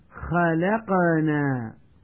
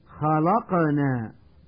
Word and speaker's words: خلقنا: والآن مع الدرس الرابع والعشرين ومع القراءة الصوتية الثانية والتي سأحاول من خلالها تنبيه المتعلم إلى الأخطاء النطقية الشائعة في الكلمات التالية: خلقنا، ويقول من يخطئ خلقنا بتفخيم حرف اللام،